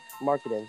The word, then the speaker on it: Markkanen: I can't pronounce his last name. – Lauri Markkanen? – Markkanen, thank you.